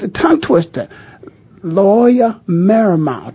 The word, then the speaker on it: Loyola Marymount: Loyola Marymount.